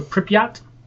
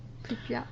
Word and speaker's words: Pripyat: Yeah, so this is called a Pripyat. That's how you pronounce it. – Pripyat.